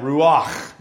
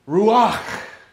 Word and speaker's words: ruach: And you're supposed to pronounce it that way, by the way. According to Barry Kirchhoff, who did a lesson on this based on John chapter 3, you're supposed to pronounce it with a little bit of that glottal sound, that ruach, because it's supposed to sound like breath.